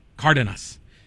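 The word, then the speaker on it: Cárdenas: Cardenas. The accent's on the first A.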